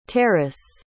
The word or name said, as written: terriss